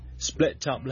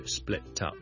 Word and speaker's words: split up: 第一件事要留意嘅呢， split up 呢个 T 可以 link 埋落去 split up split up，但係如果你係好英国 local 唔係好所谓 receive pronunciation 嘅话呢，你呢可以就咁食咗个 TM 就用 split up split up。